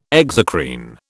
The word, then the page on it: exocrine: British English